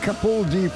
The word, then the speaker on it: Capaldi: Jim Capaldi from 1975 and Love Hurts.